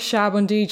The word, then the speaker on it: Sabaody: So I'm going with Sabaody just because it's easier to say, and I just like it better, I guess.